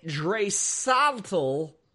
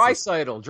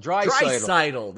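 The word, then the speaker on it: Draisaitl: Jay Lane says Jimmy Dresaltel was winning the Hart Trophy, right? – Who? – I don't know how to pronounce it. – Oh, Dreisaitl. Dreisaitl.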